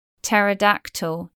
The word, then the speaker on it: pterodactyl: PT spelling from Greek is silent P. Pterodactyl.